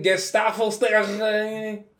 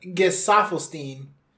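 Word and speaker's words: Gesaffelstein: Next song, The Weeknd and Gustavo Stegen. – Let's be real. Get. – How you pronounce that? – Gesafelstein.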